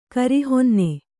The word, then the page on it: ಕಿರಿಹೊನ್ನೆ: kirihonne